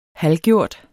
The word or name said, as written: halvgjort